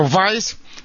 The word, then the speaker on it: Weiss: You know, in German, they pronounce W as V. His name is Dr. Weiss, W-E-I-S-S.